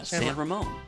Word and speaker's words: San Ramon: Yeah, San Ramon.